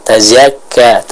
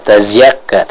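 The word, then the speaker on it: tazakka (تزكى): Selanjutnya untuk Umu Abdullah di Solo ya, kita perhatikan di ayat yang ke-14 man tazakka ya, maka huruf zainya dibaca dengan jelas ya. Tadi yang Umu Abdullah baca zainya seolah tercampur dengan huruf ya, tazakka, tazakka, tidak, bukan zia tapi za, seperti itu ya, bukan zia tapi za.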